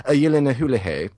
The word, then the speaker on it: Gyllenhaal: I got to say that the sister Gyllenhaal, which I've just recently heard the proper way to pronounce their name is Yelena Houlihan.